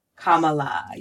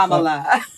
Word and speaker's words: Kamala: Kamala. Yes. – Kamala. – We want to make sure we say it right because they seem determined not to, right? – Exactly. – Kamala.